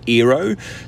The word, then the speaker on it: Eero: My name's Eero. Some might say it rhymes with hero, aka Luke as well.